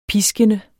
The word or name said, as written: piskende